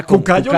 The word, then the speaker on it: cucayo: Se, o diga cucayo mejor. – Sí. Cucayo. – Ah, cucayo. Cucayo. Mire, cucayo lo pronuncia bien.